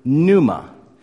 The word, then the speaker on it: pneuma: It comes from the Greek word pneuma. Now, the P is silent in that.